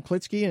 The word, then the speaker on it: Klitzkie: Tom Klitzkie, and am I pronouncing that correctly? – Yes.